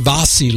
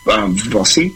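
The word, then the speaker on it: Vassy: Did you say Vasi? – Vasi. How do you pronounce it? She's from Australia. V-A-S-S-Y. I think it's Vasi.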